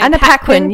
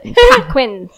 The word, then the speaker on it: Paquin: Anna Paquin. You got it. – Paquin. – Yeah. Think Pac-Man, but. – Paquin.